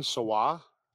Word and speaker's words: Sawa: Starring Devin Sawa. Am I saying that right? – Yes. – Yes. – It's sour, dude.